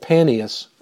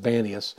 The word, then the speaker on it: Banias: And we see that if you've ever been to Israel, and you go to a place called Paneas, or Banias, they pronounce it Banias, which is up near Caesarea Maritime, you walk and you'll see this cave.